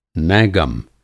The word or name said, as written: नैगम